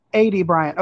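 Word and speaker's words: Aidy Bryant: And then is it Addie Bryant or Addie Bryant? Addie Bryant? Addie Bryant. Okay. See, I wasn't sure how to pronounce her name,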